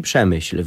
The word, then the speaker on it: Przemyśl: Jeżeli Przemyśl wymawia się jak kichnięcie, to żeby oddać autentyczne brzmienie słowa Lwów, musicie naśladować szczeknięcie ujadającego głębokim basem mastifa.